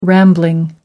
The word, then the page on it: rambling: American English